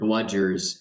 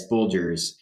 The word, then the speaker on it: bludgers: There is an Australian poem that one of these mountaineers had memorized and was reciting on a climbing trip, and he mispronounced the word bludgers as bulgers, and it stuck.